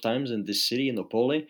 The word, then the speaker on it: Opole: Opole.